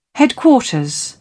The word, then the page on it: headquarters: British English